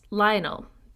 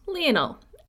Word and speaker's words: Lionel: I have pronounced his name earlier as Lionel, but I have since learned, via Catherine Warner's book, John of Gaunt, that it's pronounced Lionel. As she says, quote, Lionel Messi, not Lionel Richie, end quote.